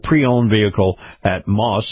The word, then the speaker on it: Maus: You've got the Moss Nissan dealership, and then right next door to it, you've got the Moss Acura dealership. And Moss is spelled M-A-U-S.